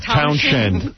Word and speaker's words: Townshend: You know, Townsend? – Yeah, Townshend.